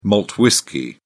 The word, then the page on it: malt whisky: British English